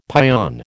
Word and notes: pion: American English

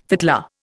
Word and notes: fiddler: Indian

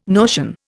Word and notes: notion: Indian

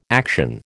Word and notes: action: American English

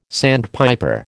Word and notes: sandpiper: American English

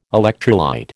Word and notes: electrolyte: American English

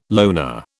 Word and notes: loner: British English

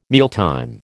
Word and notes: mealtime: American English

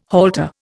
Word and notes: halter: Indian

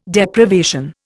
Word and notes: Deprivation: Indian

